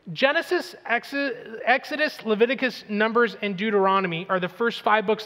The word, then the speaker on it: Deuteronomy: And by the way, if you ever need to know how to pronounce Deuteronomy, just say, dude, you're onto me.